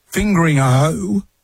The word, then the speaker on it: Fingringhoe: Spare a thought for the people over in Essex at Fingringhoe. That's the name of the town, Fingringhoe. – How do you spell it? – F-I-N-G-I-R. – Start again. – F-I-N-G-R-I-N-G-H-O-E.